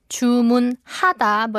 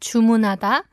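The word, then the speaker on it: 주문하다: If you enunciate each syllable, it's chumunhada. but if you say it naturally, it sounds like chumunada.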